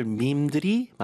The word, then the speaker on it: meme: 스펠링이 좀 특이해요. M-E-M-E 이건데, 네. – 읽을 때는 그냥 매매가 아니라 밈으로 읽어요.